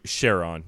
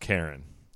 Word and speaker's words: Charon: Oh, no, Charon. You're right. You're very close to right. It's C-H-A-R-O-N, Charon. – Yeah. – Charon. – Whatever. – But now it's just pronounced Karen.